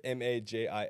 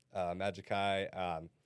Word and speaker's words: Majixkai: It's Majixkai. It's just my name. Majixkai.